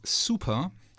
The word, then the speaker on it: SPR: It's called SPR, but we decided that it's pronounced super.